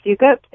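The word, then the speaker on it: Soukup: Yeah, it's Ruth Sukup. It's S-O-U-K-U-P, I believe, is the last name.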